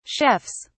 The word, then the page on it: chefs: British English